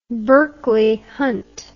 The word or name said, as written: berkeley hunt